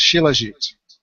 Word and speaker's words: Shilajit: And the fourth picture is a reminder that we also have the original thing called Shilajit. That's spelled S-H-I-L-A-J-I-T.